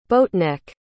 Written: boatneck

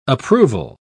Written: approval

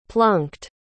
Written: plunked

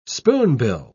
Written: spoonbill

